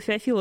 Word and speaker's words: Феофил: Но, кстати, Феофил и Феофил — это не церковное и не церковное произношение? – Да нет, просто Феофил.